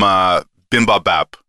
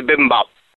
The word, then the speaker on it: bibimbap: I don't know how it works with Guinness, but give me some bibimbap. No, I said that wrong. How do you say it? – I think it's bibimbap. You were close, though.